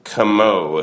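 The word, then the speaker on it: Cameaux: Yeah, Makeo George with NCOJ, Deidre Connor with the Nonprofit Center, Noelle Camot, if I'm pronouncing that right, in the French EAUX pronunciation.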